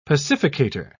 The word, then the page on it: pacificator: British English